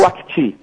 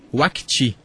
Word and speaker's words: Wakati: Como é que é a pronúncia? – Grupo Wakati. – Wakati. – Isso.